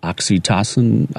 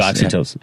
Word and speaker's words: oxytocin: 111 hertz, which some people call the God frequency or the angel frequency, can stimulate endocrine glands, producing hormones such as oxytocin. How do you say that word? – Oxytocin. – There you go, oxytocin.